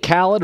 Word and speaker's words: Khaled: Can I get DJ Khaled, rhymes with salad? – And 4 bars.